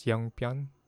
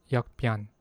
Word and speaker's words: 역변: Is it pronounced as 영변? If we... I'm not sure. 정변, 역변. Please wait. Okay, so yeah, it's pronounced as 역변. Because, you know, sometimes the 기역 can sound like NG, 영. But yeah, I guess here it's 역변.